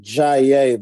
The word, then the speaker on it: Jaaye: My name is Jaaye, though.